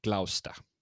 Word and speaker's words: Gloucester: Eh, ja, jag var tvungen att, jag googlade hur man uttalar det, och det är Gloucester.